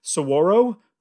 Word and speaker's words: saguaro: There are some cactus, I think it's pronounced saguaro, but I always make fun of it and say saguaro because that's literally how it's spelled.